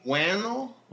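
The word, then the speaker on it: Whannell: It's, actually written and directed by, Lee Winnell. Does that sound right? I'm probably pronouncing it wrong, but I'm saying Lee Wannell. – Wannell? Oh yeah. – W-H-A-N-N-E-L, Wannell.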